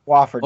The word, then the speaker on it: Wofford: Their first games are against Abilene Christian, which I'm very surprised they made it, and against Wofford, I believe I'm pronouncing that right. – Wofford, that's correct.